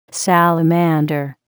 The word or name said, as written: salamander